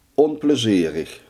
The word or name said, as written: onplezierig